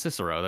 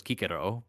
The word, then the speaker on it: Cicero: So his name wasn't pronounced Cicero. That's totally wrong. His name was Kikero, but like, don't fucking call him Kikero.